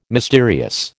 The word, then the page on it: mysterious: American English